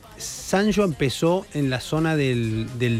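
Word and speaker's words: Sanyo: En Argentina se pronuncia Sanyó. – Sanyó. – No Sanyó.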